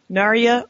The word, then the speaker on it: Narya: If you were offered Nenya, Narya, or Vilya, and I don't know if I'm pronouncing it.